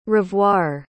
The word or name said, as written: revoir